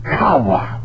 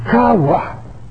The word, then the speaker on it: chayah: Kawa. Kawa. It is spelled C-H-A-Y-A-H.